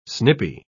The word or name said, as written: snippy